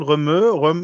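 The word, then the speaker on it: Rømø: Wir waren nämlich in wie wird es ausgesprochen, Römö. Mhm. Weiß dieses R, O durchstrichenes O, M durchstrichenes O, wo man mit dem Wohnmobil Ö, ne? – Wasser fahren kann. – Durchgestrichene O ist ein Ö, ne? – Bitte?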